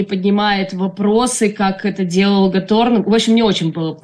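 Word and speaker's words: Готорн: Кто-то Гаторн говорит, кто-то Готтерн. Я не знаю, как правильно. – Натаниэль Гаторн, да.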